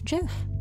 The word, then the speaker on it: GIF: So if GIF, I'm not pronouncing GIF wrong. It's GIF. You know, it's what the creator of GIF said that you pronounce it as GIF.